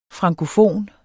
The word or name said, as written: frankofon